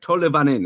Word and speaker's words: Tolvanen: And by the way, I remember last year, Brian and I looked it up and made sure it's Eli Tolvanen. Something like that. It was like Tolevanen. It's like an extra syllable.